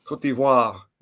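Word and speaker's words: Côte d'Ivoire: How do you pronounce that? – Cote d'Ivoire, the Ivory Coast.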